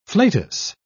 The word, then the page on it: flatus: British English